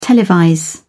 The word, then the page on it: televise: British English